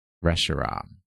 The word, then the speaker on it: Reshiram: Reshiram. – Reshiram. – No, it has to rhyme with Zekrom and Kyurem. Zekrom and Reshiram.